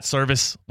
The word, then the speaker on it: Servais: I got a correction. It's Scott Servais with the Mariners, by the way. So the guy gets fired and he gets his name mispronounced here on Theoretically Speaking.